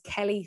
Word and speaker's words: Kellie: My name on that, or my handle on that, is Kelly Fennell Fitness, and Kelly is spelled K-E-L-L-I-E.